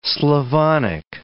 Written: Slavonic

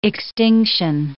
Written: extinction

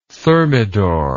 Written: thermidor

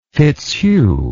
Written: fitzhugh

